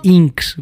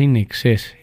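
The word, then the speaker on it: INXS: Me acuerdo que una vez dije Inks, güey. y me manda a decir, y es la verdad, y me manda a hablar don Adolfo y me dice, no se dice Inks, se dice Inexes.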